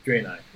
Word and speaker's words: Draenei: I am currently playing, is it pronounced Draenei? – Draenei.